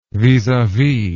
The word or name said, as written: vis a vis